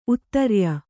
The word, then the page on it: ಉತ್ತರಾಯಿ: uttarāyi